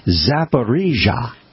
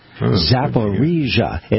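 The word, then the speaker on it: Zaporizhzhia: Yes, I'm now going to display my acuity at pronouncing that word. It's Zaporizhia. Zaporizhia. It looks like Zaporizhia.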